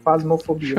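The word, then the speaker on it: Phasmophobia: que se escreve com, onde tem o som do F, é PH as duas vezes, faz e no fobia, Fasmofobia, Show.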